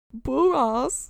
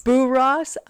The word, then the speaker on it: Borås: Borås. – Borås. I looked it up. – Uh-huh. – It's spelled B-O-R-A-S. – Uh-huh. – And above the A, there's a circle. – Ooh, Borås. – Borås. – Borås.